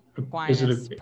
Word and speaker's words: Aquinas: But like if you go to like St. Thomas Aquarius, I'm sure they sell out every fucking game. But like, you say St. Thomas Aquarius or Aquinas? – Aquinas, probably.